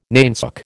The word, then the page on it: nainsook: American English